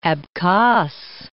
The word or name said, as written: Abkhas